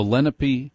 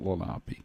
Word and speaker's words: Lenape: Even the Lenape Indians, and that's how it's pronounced, it's not Lenape,